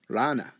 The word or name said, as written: rana